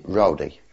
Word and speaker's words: Rodi: And then we went to and made camp at a place called Rodi. – Rodi? – Rodi. – How do you spell that? – I think it was R-O-D-I.